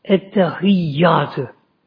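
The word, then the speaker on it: Ettehiyyatü: Şeddeler onunla dikkat edin. Ettehiyyatı. Yeri şeddeler böyle.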